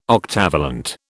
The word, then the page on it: octavalent: British English